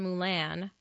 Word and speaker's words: moulin: We hiked for 20 minutes before coming upon a giant hole in the glacier, a moulin, pronounced moulin, French for mill.